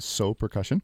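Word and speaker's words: Sō Percussion: That is spelled S-O. The O has a line over it so that you hopefully know that it is So Percussion.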